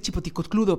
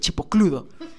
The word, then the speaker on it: chipocludo: iba a decir chipoticocludo, pero así no se dice, se dice chipocludo.